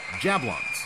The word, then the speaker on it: Jablons: Jablons. Jab rhymes with cab, and Lons rhymes with Fonz.